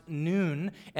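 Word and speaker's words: noon (نون): It's actually pronounced noon, and it's the 14th letter of the Arabic alphabet, which is equivalent to the English letter N.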